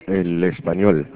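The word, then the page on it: español: Spanish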